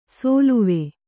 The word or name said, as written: ಸೋಲುವೆ